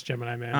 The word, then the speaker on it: Gemini Man: Is that how it is? I thought it was Jiminy Man. – No, it's Gemini Man.